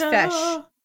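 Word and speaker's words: Feis: It's pronounced Fesh. – Fesh. – The horse, okay. – Yes, they have a pronunciation in the second paragraph. Nice. Fesh.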